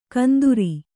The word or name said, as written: ಕಂದೂರಿ